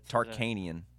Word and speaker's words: Tarkanian: Tarkanian, right? Jerry? – Yeah. – I might say his last name wrong. – No, that was right.